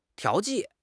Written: 调剂

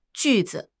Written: ju4 zi5

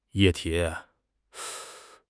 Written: 液体

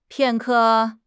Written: 片刻

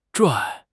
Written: 拽

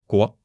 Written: guo2

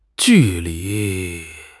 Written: ju4 li2

